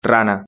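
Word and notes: rana: Spanish (Spain)

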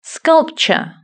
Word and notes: sculpture: British English

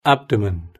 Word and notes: abdomen: British English

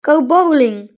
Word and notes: go bowling: British English

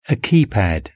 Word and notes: a keypad: British English

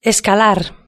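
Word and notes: escalar: Spanish (Spain)

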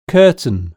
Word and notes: curtain: British English

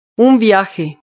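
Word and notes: un viaje: Spanish (Spain)

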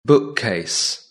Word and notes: bookcase: British English

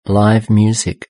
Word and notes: live music: British English

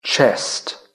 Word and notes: chest: British English

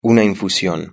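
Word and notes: una infusion: Spanish (Spain)

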